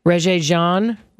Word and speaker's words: Regé-Jean: R-E-G-E, with an accent grave on the E. R-E-G-E-G-E-A-N. So, Regé-Jean, Page, P-A-G-E is a surname.